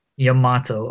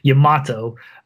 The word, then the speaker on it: Yamato: And considering that they're pairing him with, is it Yamato or Yamo? – How Yamato. Do I say it? Yamato, okay.